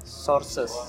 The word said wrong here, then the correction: Scorsese: bacanya apa Sorses. Sorses. nah, gue Scorsese sih gue Scorsese gue nggak tahu di Scorsese lah mm -hmm.